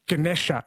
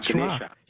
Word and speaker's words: Ganesha: Yeah, so the A at the end is pronounced like a schwa, Ganesha. – Yeah, they delete the A's. – Even the first A is almost a schwa, Ganesha.